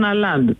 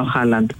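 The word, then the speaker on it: Haaland: Βρε τον, ποιος είναι, και λέγανε Αλάντ, βρε ποιος είναι ο Αλάντ, και μετά καλωδίωσα μετά από την επόμενη μέρα ότι ήταν ο Χάλαντ. Αλλά έτσι τον προφέρουν οι Γάλλοι.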